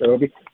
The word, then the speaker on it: Obi: The Deserts of California by, is it Obi Kaufman? – Yeah, I think it's pronounced Obi. Yeah, this is another one of these.